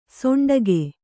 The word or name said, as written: ಷಂಡಕ